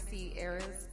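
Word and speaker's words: Cierra: Okay, so my name is Sierra's Interlude on Instagram.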